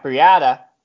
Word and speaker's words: Breida: Or Matt Breida. Rhymes with Chiata in the 14th.